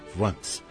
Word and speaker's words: Quantz: Se escreve Quantz, mas conselheiro de língua alemã, o Hermano Velta, me chamou a atenção de que o QU, no caso, aí se pronuncia quase como V, Quantz.